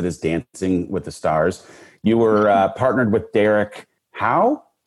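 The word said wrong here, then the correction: Hough: Is that what you say his name? Huff? – Derek. – See, this is how out of touch I am with like contemporary anything. Derek Huff.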